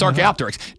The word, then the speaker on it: Archaeopteryx: It's Archaeopteryx.